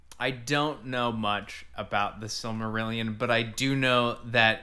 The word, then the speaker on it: Silmarillion: The Silmarillion.